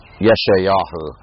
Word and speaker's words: Yeshayahu: Though actually it comes out Yeshayahu is how you say Isaiah, which sounds nothing like Isaiah.